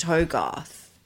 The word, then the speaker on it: Hogarth: I'm saying I thought I remember listening to the Corona Diaries and H explaining that even though his name was spelt with a double G, so you'd think it would be Hogarth, it's still pronounced Hogarth.